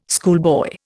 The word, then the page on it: schoolboy: Indian